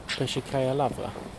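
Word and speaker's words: Pesherkaja Lavra (Печерська лавра): Pesherkaja Lavra. Pesherkaja Lavra. – Ja. Så, het, så, så uttalas det.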